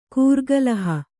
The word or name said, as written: ಕೂರ್ಗಲಹ